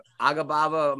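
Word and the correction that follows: Agapova: I'm butchering the name, but she, the girl out Agababa outlanded. – Oh, Akapova.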